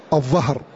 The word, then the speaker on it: الظهر: كذلك من هذه الألفاظ التي نبه الناظم عليها رحمه الله تعالى لفظ الظهر, لفظ الظهر وهو خلاف البطن, بطن وظهر, فالظهر بالضاء ولا ينطق بالضاد, والعوام ينطق بالظهر, العوام ينطقون بالضاد, طبعا هذا خطأ عند كتاب الله جل وعلا ينطق ويتلقى كما نزل.